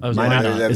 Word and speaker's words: Minot: Minot? Mino? M-I-N-O-T? I've read it, but I haven't heard it said. – Minot. – Is it Minot? Okay. He's now a journalist at the Minot Daily.